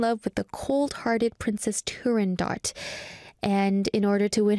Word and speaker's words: Turandot: Firstly, I'm going to talk about Turandot, the opera. We talked about this on Culture Walk. Turandot was just in Korea recently in the summer in July, and Turandot, or the Italian pronunciation is Turandot.